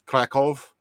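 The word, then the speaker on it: Kraków: that it's Krakow is how you're actually supposed to pronounce it.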